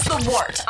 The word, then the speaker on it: Wort: This is The Wort on Govs Radio. – It is The Wort, not wart, not fort, not short, The Wort.